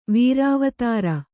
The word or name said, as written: ವೀರಾವತಾರ